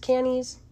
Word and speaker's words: Cannes: It was at the I think it's pronounced Cannes. I'm pretty sure the Cannes Film Festival.